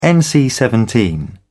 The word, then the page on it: NC-17: British English